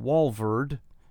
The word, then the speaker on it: Walvoord: Everybody here in our office can see Daniel, commentary by John Walvoord. And if you're writing this down, if you're where you can jot either on your smart pad or cell phone or a notepad, W-A-L-V-O-O-R-D, Voord, but it's pronounced Walvoord. He was president of Dallas Seminary when I was a student. Walvoord and Dyer, D-Y-E-R, still very, very much active in prophecy studies.